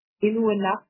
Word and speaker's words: Inuit: Again, did I say that right? I know I didn't. Or Inuit as well. How do you say, how do I say Inuit, the language? Thank you. – Inuit. Yep.